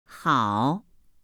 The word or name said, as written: hao